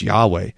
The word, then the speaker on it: Yahweh: G-O-D, that what's beneath it in the Hebrew is the divine name, the tetragrammaton, the personal name of God, sometimes pronounced Yahweh, the name by which the Lord reveals himself.